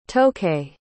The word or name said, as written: tokay